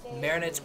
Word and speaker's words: Marinette: I really love Marionette. – And add an extra syllable.